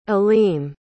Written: aleem

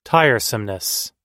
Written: tiresomeness

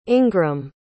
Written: ingram

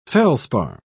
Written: feldspar

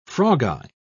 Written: frogeye

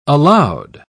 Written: aloud

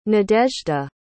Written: nadezda

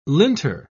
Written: linter

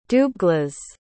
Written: dubhglas

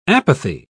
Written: apathy

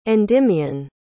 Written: endymion

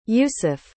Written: Yousef

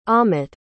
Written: amet

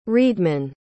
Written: readman

